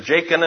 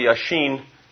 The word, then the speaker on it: Jachin: Most people pronounce it Jachin and Boaz. It's probably Yashin and Boaz.